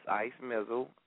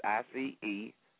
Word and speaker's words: Ice Mizzle: The name is Ice Mizzle. Spell it for you. It's ICEMIZZLE.